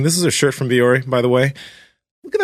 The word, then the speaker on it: Vuori: And the shorts that we're always wearing are from a company called Viori. That's spelled V-U-O-R-I. But Viori has amazing clothes for the gym that we wear, but also outside the gym.